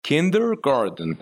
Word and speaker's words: kindergarten: دیه but it is not D it is T kindergarten